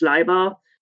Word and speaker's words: Leiber: Howard or Fritz Leiber, and whom I can pronounce correctly, yes, the German way.